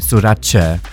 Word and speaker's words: Sorace: It's like Versace. Versace is pronounced Versace. Sorace.